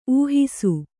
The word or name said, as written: ಊಹಿಸು